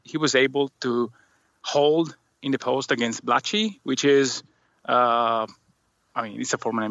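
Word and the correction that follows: Blatche: He's even bigger Andre Blatch, yeah. – Blatch, sorry. Sorry, I mispronounced that. Blatch, yes.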